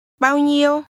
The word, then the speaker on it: nhiêu: Bao nhiêu? Nhiều. – Remember, much is pronounced with a mid-level tone.